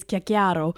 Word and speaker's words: Cicero: So the phonetic Italian pronunciation is chiacchiaro, which means chatterbox or gossip.